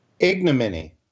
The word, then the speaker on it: ignominy: And I'm going to predict that he leaves office in a state of total ignominy. I can't pronounce that word because I'm tired. But you get the idea. – Ignominy. – Ignominy. Thank you.